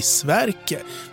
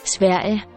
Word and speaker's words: Sverige: Vi kan ju konstatera att namnet på vårt land faktiskt uttalas på danska idag. Enligt svenska ljudregler så borde det bli Sverke. Men med dansk försvagning av G som i Tilbage och sånt här så kan det bli Sverige.